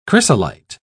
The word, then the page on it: chrysolite: British English